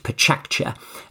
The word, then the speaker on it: Pecha Kucha: It's pronounced Pachakcha.